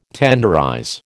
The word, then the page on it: tenderize: American English